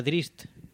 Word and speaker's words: Drizzt: Que por cierto, leí por ahí que se pronuncia Drizzt. Aunque yo siempre he pronunciado Drizzt.